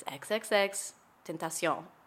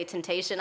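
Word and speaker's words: XXXTentacion: And he said, it's XXX Tentacion. And I was like, oh, okay. Got it. And so anytime somebody would say Temptation, I would be like.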